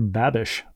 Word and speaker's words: Babish: Am I pronouncing his surname correctly? – B A B I S H? Yes.